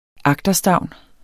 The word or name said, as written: agterstavn